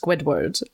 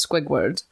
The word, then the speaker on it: Squidward: Like last episode it was revealed that I mispronounced Squidward as Squigward.